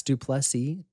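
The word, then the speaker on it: Du Plessis: Silent S, Duplessis. Dricus Duplessis.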